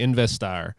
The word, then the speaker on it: investire: You just pronounce it like it looks. – Okay, investire.